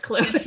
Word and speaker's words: Clwyd: Oh, which is spelled like alphabet soup, but it's pronounced Clwyd.